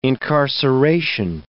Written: incarceration

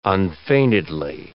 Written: unfeignedly

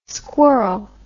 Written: squirl

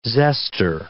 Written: zester

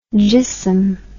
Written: jissom

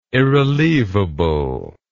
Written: irrelievable

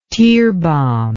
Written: tearbomb

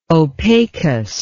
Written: opacus

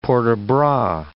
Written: port de bras

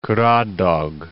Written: Caradoc

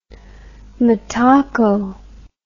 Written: Mataco

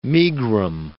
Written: megrim

